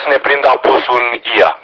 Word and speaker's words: Oia: Oia apare pe hartă, înțeleg că se pronunță Ia, cum pronunță ei aici și intern.